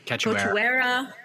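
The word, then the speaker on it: Cachoeira: I cannot pronounce her last name. It's like Cachuera. – Yeah.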